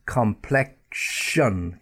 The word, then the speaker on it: complexion: And then also the K sound followed by the SH sound, sh, yeah, K, and that comes in words like complexion. Get that SH, complex, yeah, that K, complexion, complexion. Again, complex.